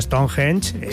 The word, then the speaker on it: Stonehenge: Que tengo mi propio calendario, sí, pero hombre, rodea siempre un halo de misterio a Stonehenge.